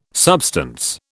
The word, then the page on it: substance: British English